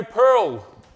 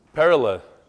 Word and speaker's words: Perla: Perla, not pearl. In English, it's pearl, perla.